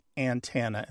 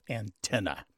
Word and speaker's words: antenna: There's something about his Southern heritage and his Southern linguistic heritage, which may lean him towards saying antenna instead of antenna. And here's a couple things to say. First of all, he's not the only one. There are other people online who talk about that pronunciation in their own language. Let me ask you another question. Does he also say cement and police and insurance? Putting the stress on the first syllable?